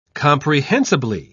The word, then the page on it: comprehensibly: British English